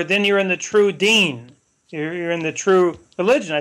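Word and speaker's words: deen (دين): He said, well, religion in Arabic is deen, pronounced deen, almost like the man's name in English, deen.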